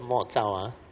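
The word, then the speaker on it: Mo Jiao (默照): Mo Jiao, or Mo Jiao, okay. They pronounce as Mo Jiao for the past 17 years.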